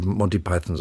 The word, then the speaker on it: Python: Monty Python haben sich ausgesucht. Sagen Sie Python oder Python? Sind wir wieder bei der Aussprache? – Ich würde Monty Python sagen.